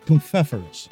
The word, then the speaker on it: Pfeffernuss: It's Pfeffernuss. Pronounced with the P in front.